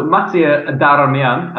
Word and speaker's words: Mattia Darmian: So Mattia Darmian, as we pronounce it, the Italian way.